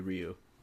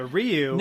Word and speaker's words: Ryu: Ryu, is it Ryu or Ryu? I've always been confused. – I always called it Ryu. – I called it Ryu when I was a kid. The Japanese pronunciation would be Ryu. – So Ryu nailed it.